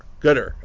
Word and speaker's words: Qatar: Qatar, actually, is how you pronounce it. Qatar.